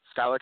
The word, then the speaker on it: Skylar: My name is spelled S K Y L A R uh, a lot of people spell it ER, but my mom liked to be different, so she spelled it AR.